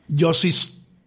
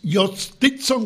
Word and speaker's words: Jhostynxon: Oigan este nombre: los Piratas de Pittsburgh ascendieron al prospecto venezolano Yostison García. Mira cómo se que escribe. – Repite ese nombre. – El nombre, por favor. – Yostison García. Se escribe con J-H-O-S-T-Y-N-X-O-N.